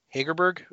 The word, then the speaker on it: Hegerberg: The first year, which was the twerking, was Ada Hegerberg. Is that how would you pronounce it? Yes.